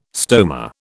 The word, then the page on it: stoma: British English